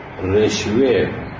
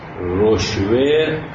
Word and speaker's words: رشوه: در کتاب قاموس جلد 4 صفحه 334 در مورد رشوه چنین گفته است که هر رشوه مسلطتون، رشوه مسلطه، رشوه رشوه رشوه هر سه نوع تلفظ شده.